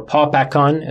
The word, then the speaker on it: Babakan: Ardashir Babakan. – Ardashir, from the line of Babak. Ardashir Babakan, or Papakan is another way of pronouncing it.